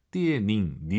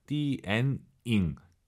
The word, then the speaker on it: 电影: 띠 에 닝 니 음 발음 들리 면안 돼요 띠 엔 잉 띠 엔 잉 다 시들 어볼 게요